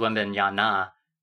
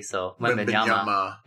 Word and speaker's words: Wembanyama: Which, by the way, I was saying Wembanyama's name wrong. I thought it was Wembanyana, but it's Ma, ends with M-A, so Wembanyama.